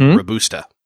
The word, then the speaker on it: Robusta: Yes, and I knew this before I read the story in the book, but it's, and I hope I say this right, but it's Arabica and Robusta. – You got it. You nailed it.